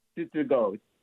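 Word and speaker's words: Tsutsugo: His name is Yoshitomo Sugo. Okay, and now Yoshitomo what? Tsugo.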